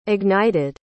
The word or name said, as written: ignited